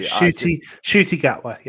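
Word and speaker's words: Ncuti Gatwa: And then I get online, and lo and behold, it's Shruti Gatwa. I really hope I'm pronouncing his name correctly. – Yeah, Shruti Gatwa, yeah.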